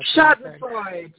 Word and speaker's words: Schadenfreude: We've got the, I can never pronounce the goddamn German word, express, Schadenfreude or whatever, however you pronounce that. – Schadenfreude. Yes, indeed.